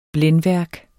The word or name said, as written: blændværk